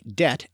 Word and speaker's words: debt: This is when, for instance, the silent letter B is added to words like debt and doubt because it shows they came from the Latin words debitum and dubitare, even though they were never pronounced that way in English.